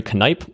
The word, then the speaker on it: Knipe: Is Henry Knipe, and you actually pronounce the K, so it's not a silent.